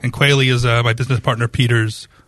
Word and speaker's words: Quealy: You do. Quealy. – Yeah, like the bird.